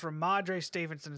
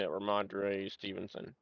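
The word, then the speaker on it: Rhamondre Stevenson: Nate, if you have me watching, I think that's Ramondre Stevenson is how you pronounce his name. – Yeah, Ramondre Stevenson.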